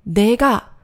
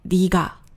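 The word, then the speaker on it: diga (네가): 所以呢，这第一个字不是 dega，而是 diga。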